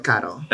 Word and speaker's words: Caro: It's called Caro.